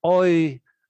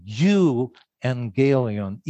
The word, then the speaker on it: euangelion: Gospel, the Greek word is euangelion, you angelion, EU. We know that from euphoria, right? Or utopia.